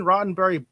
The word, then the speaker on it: Roddenberry: But this Deadline article says Star Trek creator Gene Roddenberry biopic, right? That's the way you pronounce it, is in the works with You Don't Know Jack scribe Adam Mazur.